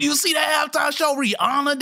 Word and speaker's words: Rihanna: It's not Rihanna. It's actually Rihanna.